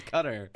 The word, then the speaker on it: Qatar: That is how you say it. It is Qatar.